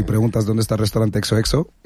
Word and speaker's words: XOXO: Bueno, la verdad es que lo dices perfecto, ¿vale? El nombre es XOXO.